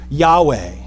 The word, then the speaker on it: Yahweh: He reveals the most intimate name of himself, this name of only consonants with no vowels, which we pronounce Yahweh.